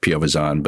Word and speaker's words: Piovesan: So it's pronounced Piovazan. But that's why I go by AJ.